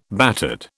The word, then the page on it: battered: British English